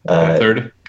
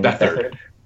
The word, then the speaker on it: Beathard: He did enough there and in the past that as soon as he's a free agent, despite CJ Beathard, however you pronounce that, Beathard? – Kyle Shanahan. – Beathard.